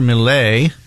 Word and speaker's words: Milei: The folks in Argentina over the weekend voted in a radical libertarian guy, Javier Milei, is how you say his name, and the world press, the deep state, and establishment is really, he's radical, he's no good, he's not going to work out.